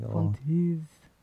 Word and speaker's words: Von Teese: maybe I'm saying her name wrong. – Dieter Von Teese or Von Teese. – Von Teese.